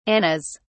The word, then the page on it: annas: British English